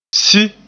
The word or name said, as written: si